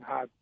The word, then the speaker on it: Hodgson: The, the, the, G is actually silent. So it's Bryan Hodgson. H O. You say it like H O D S O N.